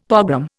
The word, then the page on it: pogrom: Indian